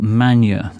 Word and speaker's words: Magne: As our befitting and emblematic debut release, Cacophonic Records presents the first in a series of rare records by French experimentalist Michel Manier, that's spelled M-A-G-N-E.